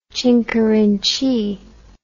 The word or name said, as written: chinkerinchee